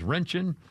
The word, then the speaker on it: Röntgen: What I mispronounced, that's Röntgen. Röntgen. That's what they call it.